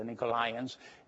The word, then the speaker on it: Nicolaitans: And that word they give you a very, word they give you a really hard time is the Nicolaitans, we pronounce it, the Nicolaitans, and as are the family of Nicol, which is in Acts 6, was a deacon in the church.